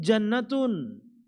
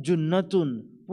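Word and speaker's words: junnatun (جنة): Coba misalnya salah baca. Karena nggak ada harokatnya, As-saumu jannatun. Puasa itu adalah surga. Lah kan repot. Karena sama-sama jim, nun dan tak marbutoh. As-saumu jannatun. Puasa itu surga. Padahal bacanya adalah as-saumu junnatun. Puasa itu perisai.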